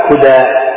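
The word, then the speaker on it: كدى: ويقال لها الآن كديس، ضروري يقال لها كديس، ولكن هي يعني تنطق هكذا كدا بالقصر مع ضم الكاف.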